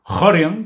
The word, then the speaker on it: Rorion: Rorion Gracie o Horion, como pronuncian en Brasil, me hace gracia porque en Estados Unidos también los pronuncian como le pronuncian en Brasil. – En Brasil dicen Rorion, dicen Horion.